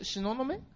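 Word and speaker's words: 東雲: はい、最初、しののめ？ – しののめ。 – しののめ。東の雲と書いて、しののめって読めるじゃないですか。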